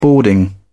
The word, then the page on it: boarding: British English